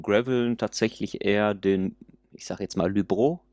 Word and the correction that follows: Luberon: ich hoffe, es ist richtig ausgesprochen. Ähm, auf Deutsch, ich, Luberon. – genau, auf Deutsch, Luberon.